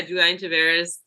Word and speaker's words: Juliany Taveras: Now, that's how I said your name, but how do you say your name? – Yes, that's pretty great. Yeah, you know, I just say, Juliany Taveras, if I'm speaking in English.